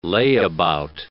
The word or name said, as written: layabout